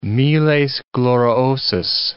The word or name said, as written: miles gloriosus